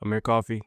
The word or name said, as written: Amir Coffey